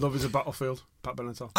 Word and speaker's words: Benatar: Pat Benatar. – Is that how you pronounce it? – Benatar, yeah.